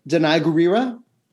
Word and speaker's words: Danai Gurira: Danai Gurira.